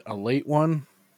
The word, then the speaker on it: Ale 81: I actually just came across some weird fucking regional soda called Ale 81, but they pronounce it a late one.